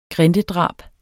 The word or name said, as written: grindedrab